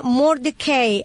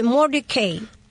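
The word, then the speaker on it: Mortdecai: Y una película de Johnny Depp que se llama Mordecai. Así se dice, amigo mío, se escribe Mordecai. Y yo dije Mordecai. Debe ser así.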